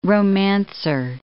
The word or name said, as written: romancer